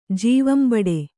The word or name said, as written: ಜೀವಂಬಡೆ